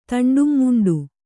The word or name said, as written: ತಂಡುಂಮುಂಡು